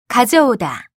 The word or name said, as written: 가져오다